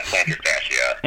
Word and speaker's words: plantar fascia: Yeah, plantar fascia.